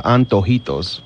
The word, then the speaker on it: antojitos: The enchiladas or the wraps or the antojitos.